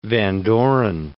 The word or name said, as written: Van Doren